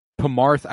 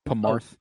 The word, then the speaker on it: Pamarth: Pamarth. I believe it's P-A-M-A-R-T-H. Pamarth. – Oh. Okay, so it's not just P-M-A-R-T-H. I thought like... – No, Pamarth. – okay. Sorry. – And the people from Pamarth are known for flying, fighting, and...